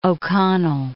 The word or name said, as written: O'Connell